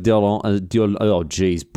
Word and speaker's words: d'Orléans: Louis-Philippe Joseph d'Orléans, butchered that one.